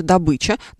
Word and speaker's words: добыча: Верно говорить, конечно же, «добыча», но мы не можем стигматизировать людей, которые ставят здесь ударение по-другому, потому что есть такая штука, как профессионализмы.